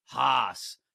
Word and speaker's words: house: And appreciate the Western Pennsylvania pronunciation of the word house. Haas.